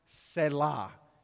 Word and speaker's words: Selah: If you read the Psalms, like Psalm 4, you'll see a little word in the Hebrew pronounced Selah. S-E-L-A-H, Selah.